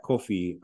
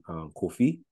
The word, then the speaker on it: Kofi: Which in phonetic English is spelled C-U, in most cases, spelled C-U-F-F-E, C-U-F-E-E. And that Kofi refers to the Akan name Kofi.